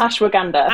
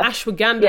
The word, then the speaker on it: ashwagandha: So, for example, this is one, maybe going off topic again ashwanga. I don't know if I'm saying it right, but this is ashwagandha. Ashwagandha, yeah. thank you. Ashwagandha.